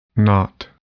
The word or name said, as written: knot